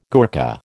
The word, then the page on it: Gurkha: American English